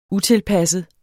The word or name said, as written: utilpasset